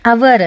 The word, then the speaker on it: hour: ಉದಾಹರಣೆಗೆ HOUR ಅವರ್ ಅಂತ ಹೇಳ್ತೀವಿ, ಇಲ್ಲಿ ಎಚ್ ಸೈಲೆಂಟ್ ಆಯಿತು.